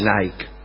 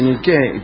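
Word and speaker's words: Niké: Quiere decir victoria, no se dice Nike, se dice Niqué, y por eso se llaman así las zapatillas, porque quiere decir victoria.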